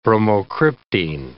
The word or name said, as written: bromocriptine